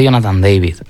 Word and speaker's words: Jonathan David: Yo es que he leído que, o he escuchado que esa es la pronunciación realmente, ¿no? – La prensa canadiense dice Jonathan David. – Ah, pues entonces suele haber mucho cuidado con los nombres franceses.